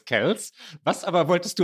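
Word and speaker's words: Kelce: Wir haben letztes Mal darüber diskutiert, spricht man Kells eigentlich Kells oder Kelsey aus und haben lange hergeleitet, warum Kells gesagt wird.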